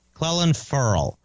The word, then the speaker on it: Clelin Ferrell: They took Colin Farrell. – Clelin Ferrell. It's a weird pronunciation on his name, and no one can figure it out.